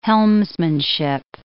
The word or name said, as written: helmsmanship